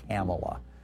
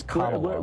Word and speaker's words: Kamala: He calls her lying Kamala. Like camel? – Yeah. – Really? It's Kamala, whatever.